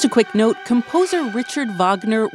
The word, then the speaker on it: Wagner: For people who might not know, what is the Wagner Group or the Wagner Group? – So I pronounce it Wagner because the neo-Nazi who founded it was inspired by the composer Wagner's professed Nazi sympathy.